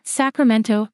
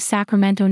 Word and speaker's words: Sacramento: In my English pronunciation of the word, I use vowels that simply do not exist in the phonemic inventory of the Spanish language. I ignore the presence of the letter T entirely, and I let the O linger just a touch longer than most native Spanish speakers would in their pronunciation of Sacramento.